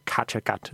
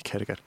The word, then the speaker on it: Kattegat: Og så Karoline Henderson spiller Jarl Håkon, lederen, chefen af Kattegat. Fordi Kattegat skal udtales sådan der. Det skal være Kattegat. Det skal ikke være bare almindelig Kattegat.